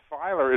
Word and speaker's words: Feiler: It's relief backwards, and you're probably, I know, of the generation that remembers the old Rolaids commercial about how you spell relief, and we used to always say, you spell it backwards, it's Feiler, and I was actually quite happy.